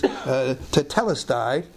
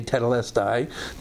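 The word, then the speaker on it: tetelestai (τετέλεσται): The Greek word here is tetelestai. Some say tetelestai, depends on your pronunciation of Greek, but it's a perfect tense of the verb teleo.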